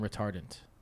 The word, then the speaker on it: retardant: It's the right way to say it. Flame retardant.